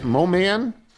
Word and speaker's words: Moman: From Murray Moman. Is that it? Mo-man? – Mo-man. – Rhymes with Roman.